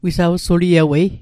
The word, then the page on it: wisawsuliewei: Mi'gmaq